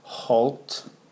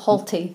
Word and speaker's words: Holt: I think it's pronounced Holt, as Jen did it. – Not Halty. – Not Halty, as the Americans like it. Halt.